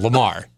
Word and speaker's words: Lamar: Hint, his name rhymes with Jafar. – As in Lamar.